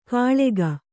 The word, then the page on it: ಕಾಳೆಗ: kāḷega